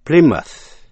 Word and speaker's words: Plymouth: Plymouth, Plymouth.